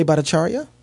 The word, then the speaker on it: Bhattacharya: Jay Bhattacharya.